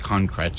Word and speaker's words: concrète: And much of that came out of the Musique Concrète, spelled like concrete, but it's en français, it's concrète.